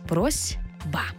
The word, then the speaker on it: просьба: Ми точно знаємо, що це слово пишеться з літерою «с». «Просьба». Але як ми його вимовляємо? «Просьба». Чуємо звук «з». Чому так відбувається? Ще раз закріплюємо правило. Глухий звук «с» стоїть поруч із дзвінким звуком «б». А глухий плюс дзвінкий шукає собі дзвінку пару.